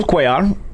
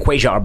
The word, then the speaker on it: Cuellar: Listen, Jesus told us in the fighter meeting he likes to pronounce Cuellar. All right, believe me, I know the double L is Ya. I know that. But Jesus wants to pronounce Ya.